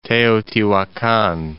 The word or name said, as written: Teotihuacan